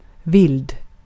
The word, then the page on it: wild: /waɪld/